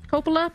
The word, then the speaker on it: Coppola: Sofia Coppola. Coppola? – Mm-hmm. – I said it right. Coppola? – Yeah.